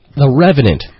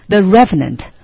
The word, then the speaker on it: The Revenant: 我们来教大家《荒野猎人》由 Leonardo, 我们的小李子主演的这部片子应该怎么念？ – The Revenant. – The Revenant. 荒野猎人或荒原猎人。 – 那说到 The Revenant, 我们一定要说说。I just asked Jingjing. I asked her. I said, Jingjing, have you seen The Revenant?